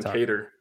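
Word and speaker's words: Kattar: We were like Jeremy Stevens and Qatar. I forget the exact pronunciation. He took Calvin Cater.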